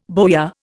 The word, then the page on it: bowyer: Indian